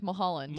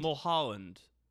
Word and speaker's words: Mulholland: Is that how we say it? I thought it was like Mulholland. – Mulholland. – Mulholland. – Am I saying it wrong?